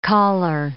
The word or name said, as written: caller